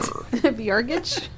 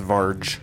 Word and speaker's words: Varge: Vyargage? – Vyargi? – It's pronounced Varge. Like Marge with a V.